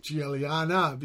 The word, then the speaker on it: Guiliana: And then we had Mark Guiliana with Julian, I should say. Yeah. Shit. – Yep. – Right. They have the soft G, huh? Giallo for yellow. – Yeah. Yeah, that Italian pronunciation.